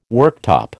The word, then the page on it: worktop: American English